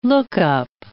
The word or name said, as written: lookup